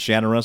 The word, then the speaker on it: Shannara: So back where I was, back east, we said sort of Shannara. – Right. And that's why I used to say it, but when I met Terry Brooks, he pronounced it Shannara, so I'm like, oh, that's how.